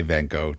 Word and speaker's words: van Gogh: And normally I would just say Van Gogh, just because it sounds, even though it's pronounced correctly, it sounds so pretentious when you throw in that little glottal thing at the end of it.